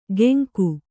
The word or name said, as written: ಗೆಂಕು